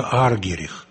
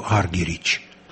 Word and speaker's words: Аргерих: Боже мой, да ведь это Марта Аргерих. Американцы произносят ее фамилию Аргерич.